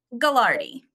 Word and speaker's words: Gagliardi: Gagliardi.